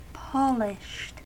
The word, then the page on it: polished: American English